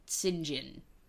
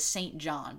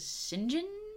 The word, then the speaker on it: St. John: Except that you're supposed to pronounce it like St. John, and I have no idea why. Like, it reads as St. John, but everyone says St. John?